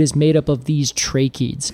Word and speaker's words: tracheids: I'll define tracheids first. – Okay. – Tracheids, and that is spelled T-R-A-C-H-E-I-D-S. – Yes. – Tracheids. – Like trachea. – Exactly like trachea.